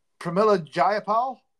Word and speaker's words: Pramila Jayapal: How do you say her name? Pramila Jayapal. – Yeah. I think you're right. – Nailed one.